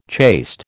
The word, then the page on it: chaste: American English